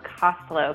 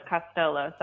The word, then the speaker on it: Costolo: It's pronounced Costolo, kind of like Costco. – Ah, but everybody says Costolo, so I just kind of go with it at this point.